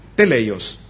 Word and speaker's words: teleios: Y la palabra que se usa aquí en el griego para madurez es teleios. Teleios. Se escribe T-E-L-E-I-O-S.